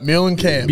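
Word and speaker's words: Meulenkamp: how would you say that word? His name. Mellencamp? – Mellencamp.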